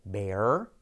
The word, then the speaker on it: bear: En este caso, bear, oso, tiene un diptongo. Diptongo es cuando se ponen dos vocales en una misma sílaba. Fíjate, ea, ea, ea, ea. Bea, como mi amiga Bea. Hola, Bea, ¿qué tal? ¿Qué tal, Bea, Bea, Bea? Bear, bear, bear. Tenemos ese diptongo y la R, bear.